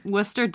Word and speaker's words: Worcester: And Worcester does have a silent R and also a C.